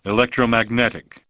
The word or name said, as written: Electromagnetic